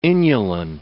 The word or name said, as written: inulin